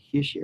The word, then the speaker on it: Hischier: Now, what's interesting on this is although Hischier, I still can't say his name right, Hischier. – Right, you said it right that time.